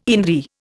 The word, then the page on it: indri: Indian